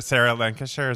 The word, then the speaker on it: Sarah Lancashire: about the casting because is it sarah lancashire is that how you say her name